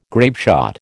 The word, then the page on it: grapeshot: American English